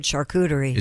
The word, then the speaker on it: charcuterie: I've always heard charcuterie. – Is it so it's got a ch sound? – Shuh. – Shuh? So it's a shuh.